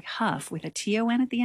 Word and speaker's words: Hough: We're going to continue this hour with a piece from Sir Stephen Huff. I'm just going to make a little disclaimer here. His name is spelled H-O-U-G-H, and he calls himself Huff, as do the dancers on Dancing with the Stars.